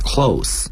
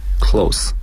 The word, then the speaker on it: close: Is that close? 应该读成 close 而不是 close，因为 close 是动词， close 在这里是形容词。